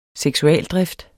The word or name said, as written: seksualdrift